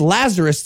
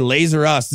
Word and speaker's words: Lazarus: You don't even say it right. It's Lazarus. It's supposed to be a reference to Lazarus. We don't even say it. We say Lazer Us.